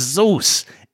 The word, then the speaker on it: Zeus: His last name is Zeus. It rhymes with booze.